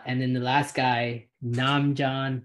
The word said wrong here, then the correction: Nemanja: Bialica. Yeah, he went in the second round here. – Nemanja. – Yeah, whatever. Butchered his first name, but got his last name right.